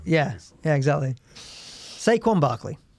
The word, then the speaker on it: Saquon: I mean, if you go to the terribleness of the draft pick that is Saquon Barkley. That is how you pronounce his first name.